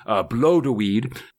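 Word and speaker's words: Blodeuwedd: And from the cauldron, the young aspect of Danu, Blodeweed, as I've heard it's pronounced,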